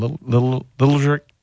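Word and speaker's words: Liljegren: And then you got your first round pick, Timothy, how do you say his name? Littlejerk? – That's it. You just said it perfectly.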